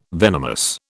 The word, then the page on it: venomous: British English